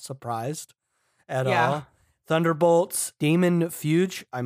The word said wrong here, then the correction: Fugue: Fugue? – Fugue, probably.